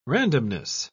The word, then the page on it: randomness: British English